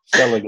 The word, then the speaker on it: Selig: Did I pronounce it correctly, Paul? – Selig is correct.